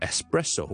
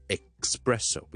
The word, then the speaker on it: espresso: Number one 最常听到人读错嘅咧就系 espresso，我哋一个特浓嘅咖啡 espresso，espresso，咁即系咧我哋喺咖啡机里面挤出嚟，跟住唔加水唔加奶，超级浓嘅嗰啲咖啡，我哋叫做 espresso。好多人咧将佢读成 expresso，变咗 ex 加咗个 k 音喺度，就唔需要嘅。我读翻 s s a 完之后直接落个 s 音就可以读，so s s s s s，好似 the letter s 咁样，espresso espresso espresso。